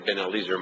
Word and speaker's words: Ben-Eliezer: Spelled Eleazar, but I think it's pronounced, they say Ben-Eliezer more often.